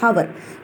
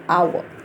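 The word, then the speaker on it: hour: अब हम कहते हैं टाइम कितना हुआ है, इट्स वन हावर, कई बच्चे कहते हैं हावर, लेकिन हावर नहीं है, यहाँ पर एच जो है वो साइलेंट है, तो एच के आगे एच देखने में कॉन्सोनेंट है लेकिन साउंड किसकी है, वोवल की साउंड है, साइलेंट एच है तो हम कहते हैं एन आवर, ठीक है.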